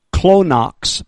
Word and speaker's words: chonix: I'm going to spell it up here, but it's pronounced this way, clonox, but it's spelled this way, C-H-O-N-I-X. This is, it's pronounced with a K-L-O.